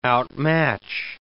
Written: outmatch

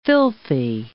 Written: filthy

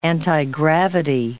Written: antigravity

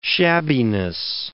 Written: shabbiness

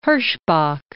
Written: Herschbach

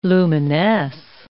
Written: luminesce

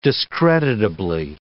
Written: discreditably

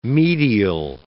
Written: medial

